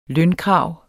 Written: lønkrav

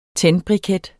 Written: tændbriket

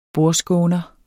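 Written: BORDSKÅNERE

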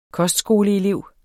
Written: kostskoleelev

